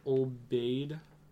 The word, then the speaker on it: Obaid: Can you repeat that name? – It's Sharmeen Obaid. – Sharmeen. That name sounds familiar. Sharmeen? – O-B-A-A-D.